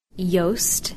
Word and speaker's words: Joost: Joost, which rhymes with ghost, and Secher, which rhymes with betcha.